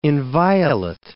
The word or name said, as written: inviolate